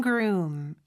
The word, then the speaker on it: groom: 자, 그리고 신랑이라는 뜻의 groom 같은 경우는 여기도 역시 room, room 요게 발음이 잘 되면 앞에 g 소리만 넣어주면 되죠. groom, room이 더 크게 들려야 돼요. 그 여기서 O가 두 개 있는데 그냥 우우 소리가 아니라 우 소리예요. 그래서 groom 이렇게 발음이 된다는 것도 기억해 주세요.